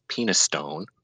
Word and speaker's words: Penistone: It's Penistone.